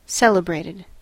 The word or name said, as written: celebrated